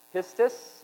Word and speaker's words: pistis: The Greek word is pronounced pistis, And it's P-I-S-T-I-S, and it means credence, conviction of religious truth, constancy of such profession, assurance, belief, fidelity.